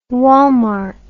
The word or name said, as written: Walmart